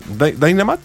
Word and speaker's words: Dynamite: Ayúdame con la pronunciación. ¿Dinamita? – ¿Dynamite? – Sí, debe ser como eso. ¿Así? ¿Ah, ¿Dynamite? Ah, perfecto. Nos vamos a ir con eso.